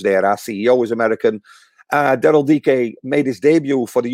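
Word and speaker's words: Daryl Dike: And then I think it was one of the last days in the transfer, they brought in Daryl DK, as it's pronounced. We all say, still say that, but Daryl DK.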